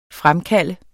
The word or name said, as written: fremkalde